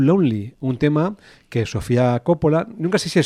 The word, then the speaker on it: Coppola: Va a venir Cavi a decirnos cómo se dice. Coppola. – Cavi. Vale, pues Coppola. – Coppola, porque me ha señalado a mí, o sea que sí.